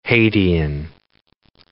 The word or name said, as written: Hadean